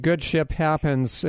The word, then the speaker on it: Good Ship Happens: And your new website, Good Ship Happens, is not yet online, but and I want right. – to make sure it's, I pronounce my P's. It's Good Ship Happens. Okay. – Yes. SHIP.